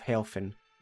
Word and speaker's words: Helphen: The spelling of Helphen may not necessarily mean anything spelled out, but phonetically it is the same as the German word Helfen, meaning help or assistance in English.